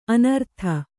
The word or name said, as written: ಅನೂರಾಧ